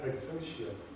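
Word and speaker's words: exousia (ἐξουσία): It comes from the Greek word, and it's pronounced exousia, meaning ruling power, having the right to do something, or the capability to complete an action.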